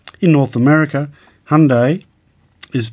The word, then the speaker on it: Hyundai: However, British English speakers are likely to use three syllables, saying the name as Hyundai. The Koreans told me you pronounce it like Sunday, but with an H, so I'll stick with what they've told me.